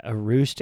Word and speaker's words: Etruscan: Holy priceless collection of Erustian schnoods, Batman. Did I say that right? Probably not. – Yeah, Etruscan. I think that's Etruscan. It's spelled E-T-R-U-S-C-A-N.